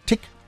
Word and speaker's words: Tich: Dafty Dozy Breaky Mick and Titchk, Titch, Tick, Tick. Hideaway, Hideaway. Ich muss aber aufpassen, dass ich das ordentlich ausspreche. Ne? T-I-C-H wird Tick ausgesprochen. Ja, so wie Tick-Tack.